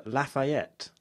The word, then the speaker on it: Lafayette: And the L stands for Lafayette. – Mm. – I think that's how you say it. – It is.